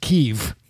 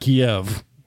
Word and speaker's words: Kyiv: what we now know is pronounced Kyiv, not Kiev. That's what they were saying yesterday in Congress. Kyiv. – It's the Ukrainian pronunciation. We adopted the Russian pronunciation.